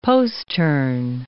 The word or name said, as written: postern